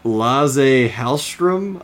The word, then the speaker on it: Lasse Hallström: Okay, so this was directed by Lasse Hallström. I may have said that wrong. – I think that sounded perfect.